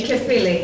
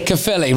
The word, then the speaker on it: Caerphilly: Caerphilly. – Caerphilly, right, there you go, all right.